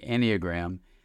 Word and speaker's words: Enneagram: And for me, when I first heard about this system called the Enneagram, I had exactly... – I'm glad you pronounced it this. – Yeah. – the correct one.